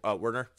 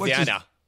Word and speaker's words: Werner: Richard Werner, for American accent. He's from Germany. – Say his name again. – Which accent do you want? – Try to say it in German. – Richard Werner.